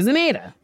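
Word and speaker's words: Zamata: And that's Sasheer Zaneda. – Yum. – Rhymes with tomato.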